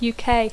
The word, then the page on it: UK: English